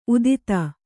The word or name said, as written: ಉತ್ತುತೆ